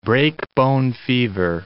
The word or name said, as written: breakbone fever